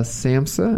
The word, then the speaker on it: SAMHSA: You recently received a SAMHSA. Am I saying that right? – Yeah, that's it.